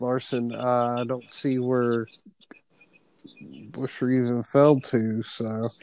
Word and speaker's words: Buescher: Chris Buescher, I think I said that wrong again. – Buescher has really done well.